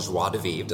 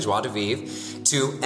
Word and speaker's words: Joie de Vivre: Really, he went from being the owner of a rather large boutique hotel company called Joie de Vivre. Did I say it right? – Exactly. – Okay, Joie de Vivre.